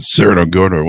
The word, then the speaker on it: Cerro Gordo: Am I pronouncing that right? There you go. – That's right. – I think I got Cerro Gordo one time from a bad burrito.